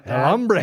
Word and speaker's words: El Hombre: The H is silent. – El Hombre. – In Spanish.